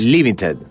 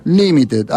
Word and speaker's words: Limited: No sé si está bien porque vos sos el experto acá y este último término seguramente tenga otra pronunciación. ¿Cómo es? – Limited. – Limited, ahí está.